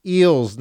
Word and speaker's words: Eels: And it's Eels, not The Eels. You have to say it properly. It's Eels.